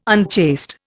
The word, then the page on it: unchaste: Indian